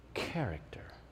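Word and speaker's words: Character (χαρακτήρ): Let me give you the Greek pronunciation. You ready for it? Character. That's the Greek word. That's how you pronounce it. Character.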